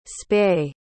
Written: spae

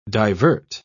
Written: divert

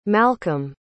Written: malcom

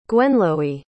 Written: guenloie

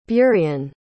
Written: burian

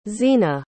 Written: zeena

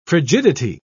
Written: frigidity

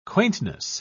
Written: quaintness

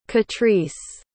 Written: katrice

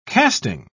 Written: casting

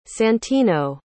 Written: santino

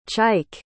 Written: chike

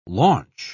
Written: launch

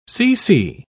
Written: cc

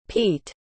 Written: pete

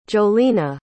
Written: jolena

